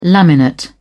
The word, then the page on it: laminate: British English